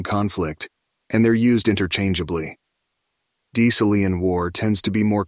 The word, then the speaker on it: Decelean: The term Decelean is generally pronounced as Decelean.